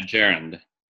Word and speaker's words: gerund: And also this chapter is on the, I don't know how you pronounce that, gerund, the having, gerund. Having done something. Yeah, gerund. – Yeah, gerund.